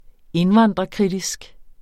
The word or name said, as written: indvandrerkritisk